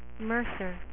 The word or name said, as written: Mercer